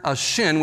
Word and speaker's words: shin: It's the Hebrew letter shin, which is pronounced with an SH sound.